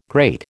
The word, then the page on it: great: American English